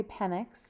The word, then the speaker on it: Penix: I know how to say Penix. Okay, I've heard it on ESPN. – I call it Penix because he rises to the occasion.